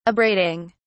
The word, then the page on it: abrading: British English